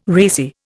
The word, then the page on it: racy: Indian